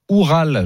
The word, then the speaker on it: Oural: Le Zénith Saint-Pétersbourg qui a étrillé Oural, je pense que ça se prononce comme ça. – C'est ça.